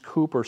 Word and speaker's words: Cowper: So the poet William Cowper, it's pronounced Cooper, spelled Cowper, C-O-W-P-E-R.